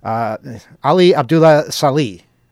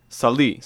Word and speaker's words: Ali Abdullah Saleh: I pronounce it, I have to say it all at once, otherwise it doesn't come out right. Ali Abdullah Saleh. – Saleh. – Saleh. Okay, I guess I could say Saleh then, yes.